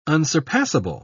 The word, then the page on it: unsurpassable: British English